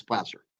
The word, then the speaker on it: Placer: You pronounced it correctly. Most people outside of California refer to it as Placer, but no, you got it. It's Placer.